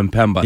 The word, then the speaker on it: Mpemba: Mario Mpebra. Am I saying that right, Ken Laird? I hope I am. Formerly the Detroit Tigers. – He threw like an extra R in there. He threw like an R in the last name.